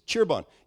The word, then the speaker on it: Cirebon: The name of the city is Cheerbon. You know what it's spelled? C-I-R-E-B-O-N.